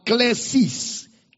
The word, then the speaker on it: klesis: Then you have another word, klesis. K-L-E-S-I-S. Klesis is the word for calling. Kaleo is the word for church. Klesis is the word for calling.